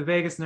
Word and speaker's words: vagus: It's the total opposite of Vegas, but it's the vagus nerve. It's spelled a little different, and it is connected to deep parts of your brain.